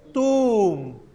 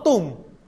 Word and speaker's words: tuum: Sanctificetur nomen tuum. No tum, tuum, dou. Digan, sanctificetur nomen tuum. – Sanctificetur nomen tuum.